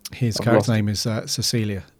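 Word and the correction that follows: Caecilius: Cecilius, yeah.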